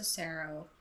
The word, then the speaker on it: serow: Is it like, possible that it was a serow? Right, I pronounced that correctly.